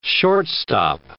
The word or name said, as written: shortstop